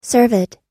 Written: cervid

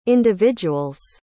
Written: individuals

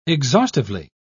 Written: exhaustively

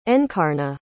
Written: encarna

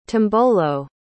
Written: tombolo

